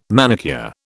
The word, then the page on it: manicure: British English